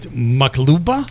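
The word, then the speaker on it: maqluba: So is it makluba? – Yes.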